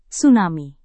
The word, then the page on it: tsunami: indonesian